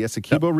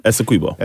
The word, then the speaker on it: Essequibo: The Essequibo region? – Essequibo.